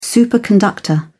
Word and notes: superconductor: British English

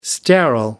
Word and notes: sterile: American English

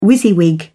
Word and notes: wysiwyg: British English

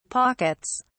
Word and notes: pockets: British English

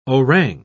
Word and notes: orang: British English

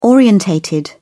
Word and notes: orientated: British English

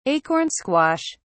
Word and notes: acorn squash: British English

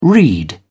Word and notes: reed: British English

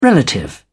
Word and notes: relative: British English

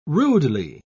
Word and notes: rudely: British English

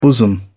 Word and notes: bosom: British English

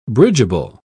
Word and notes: bridgeable: British English

